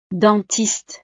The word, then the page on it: dentiste: frances